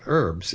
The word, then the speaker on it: herbs: And there's the first thing I want to say about herbs is, like several words that come from French, Americans try to preserve the French pronunciation. So we say herbs, we don't pronounce the H. The British, on the other hand, do. And they say herbs.